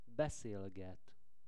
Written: beszelget

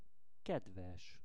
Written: kedves

